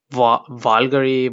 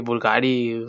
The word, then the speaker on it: Bulgari: Y no se llamaba como Vulgary, Bulgari, se escribe como Bulgari, una mamá así.